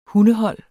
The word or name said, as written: hundehold